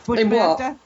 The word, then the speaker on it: Bushmaster: I love how everyone does not pronounce the R because New Yorkers would not. it's Bushmaster. – Oh, yeah, Bushmaster. – Every New Yorker, it's Bushmaster, not Bushmaster.